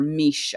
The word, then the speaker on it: Mis: And anyway, she tells the story of this young woman named Miss, M-I-S, or Mish, I guess is how you would pronounce it in the Celtic language, who's this, you know, beautiful young woman.